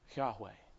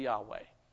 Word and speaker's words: Yahweh: have said that the best way to pronounce it is most likely Yahweh. Okay, so that's probably a pretty good way to say it. It's probably fairly accurate to original usage within the Hebrew language.